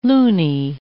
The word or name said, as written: loonie